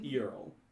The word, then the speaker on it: Eurl: This one is spelled, you can see on the embroidery that it's clearly E-U-R-L. – Earl? – Earl.